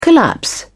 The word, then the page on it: collapse: British English